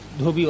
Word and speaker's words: dhobi: Trustworthy dhobi wala. How do you pronounce dhobi? Dhobi. Dhobi. Dhobi. Washerman.